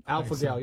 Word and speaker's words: alpha-gal: Alpha-gal, yep.